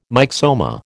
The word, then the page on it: myxoma: American English